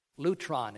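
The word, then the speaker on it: lytron: Lutron is the way we would pronounce it.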